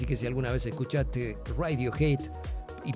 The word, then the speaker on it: Radiohead: o Radiohead, muchos en la Argentina, viste que formamos todo, pero es Radiohead, la banda británica que dejó canciones que marcaron distintas generaciones, desde Creep hasta Karma Police y Paranoid Android.